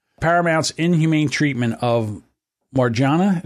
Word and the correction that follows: Margera: Margera. – Margera.